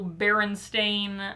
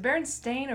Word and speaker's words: Berenstain: Is it Berenstain or Berenstein? – Oh, we're going to get into that. Oh. That. There's a conspiracy theory. – I mean, it's spelled like stain. – It is. – But I don't call it that.